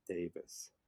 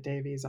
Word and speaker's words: Davies: And it was till very recently, maybe about a year ago, I realized that his name is pronounced Davis. – Yeah, yes. I was educated to that very fact within the last year myself. I've been pronouncing it Davies all this time.